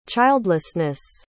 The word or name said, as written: childlessness